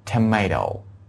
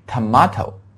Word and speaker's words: tomato: 第二组大家看一下啊，第二组 the second point 啊 second point 啊，请大家看一下，就是我们说的 POTATO potato 还有 TOMATO tomato。好，这两个词注意一下，不要读成 tomato，一般来说很多美国人都应该读成是 tomato right。